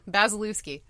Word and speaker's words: Bazylewski: Bazylewski.